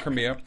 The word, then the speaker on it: Crimea: Crimea? – Sure. – Okay, we're going to go with Crimea.